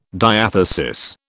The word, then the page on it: diathesis: British English